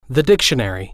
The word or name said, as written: the dictionary